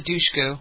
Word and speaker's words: Dushku: Is it Dushku? Is that how you pronounce her name? – Eliza Dushku. Yep.